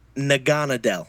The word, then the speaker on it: Naganadel: Naganadel.